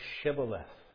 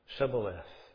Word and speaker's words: Shibboleth: They couldn't pronounce the word Shibboleth the way they pronounced it was Sibboleth. They couldn't pronounce Shibboleth, Sibboleth.